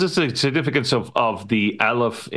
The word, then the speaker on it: Aleph: I'm pretty sure I'm pronouncing it right. It's Aleph.